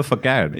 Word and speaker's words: facade: Because that's all a facade. – Yeah, facade. – Facade. I'm so sorry. – Rhymes with decade.